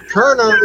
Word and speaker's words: Koerner: Koerner is a German name. The O and the E get squished together to make a U sound, okay?